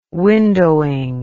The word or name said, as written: windowing